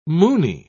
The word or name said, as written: moony